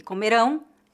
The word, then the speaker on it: comerão: A única diferença de pronúncia entre as palavras comeram e comerão é a sílaba tônica.